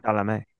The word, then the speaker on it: Chalamet: So Chalamet.